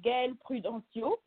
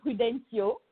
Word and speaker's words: Gaëlle Prudencio: So my name is Gaëlle Prudencio, and you can say Prudencio. It's okay.